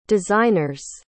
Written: designers

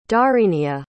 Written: dharinija

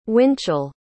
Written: Winchell

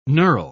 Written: knurl

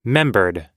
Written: membered

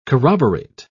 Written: corroborate

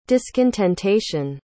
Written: discontentation

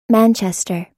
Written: Manchester

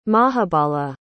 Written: mahabala

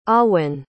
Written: amhuinn

